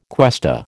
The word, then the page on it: cuesta: American English